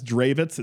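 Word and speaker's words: Drewitz: We'll do at Tyler Dravitz. It's at T-Y-L-E-R. And then even though my last name says Dravitz, it's spelt Druitz. So that's D-R-E-W-I-T-Z.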